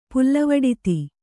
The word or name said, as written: ಪುಲ್ಲವಡಿತಿ